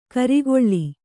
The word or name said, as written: ಖರೆಗೊಳ್